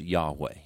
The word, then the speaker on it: Yahweh: And that's where God reveals himself with his divine personal name, which we would pronounce Yahweh.